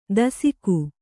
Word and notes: ದಸಿಕು: dasiku

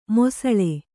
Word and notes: ಮಾಸಾಳು: māsāḷu

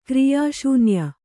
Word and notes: ಕ್ರಿಯಾಶೂನ್ಯ: kriyā śunya

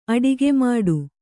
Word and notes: ಅಡಗುಮೆಟ್ಟು: Kannada

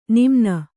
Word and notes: ನಮನ: namana

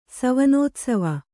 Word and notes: ಸವನೋತ್ಸವ: savanōtsava

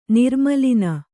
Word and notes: ನಿರ್ಮೂಲನ: Kannada

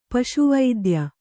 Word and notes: ಪಶುವೈದ್ಯ: paśu vaidya

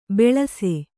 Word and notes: ಬೆಳಸೆ: beḷase

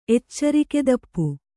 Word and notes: ಎಚ್ಚರಿಕೆದಪ್ಪು: kannada

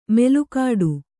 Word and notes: ಮೆಲುಕಾಡು: melukāḍ'i

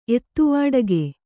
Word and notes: ಎದೆವೊಡಕ: edevoḍaka

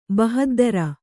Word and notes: ಬಹದ್ದರ: bahaddara

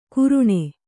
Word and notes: ಕುರುಣೆ: Kannada

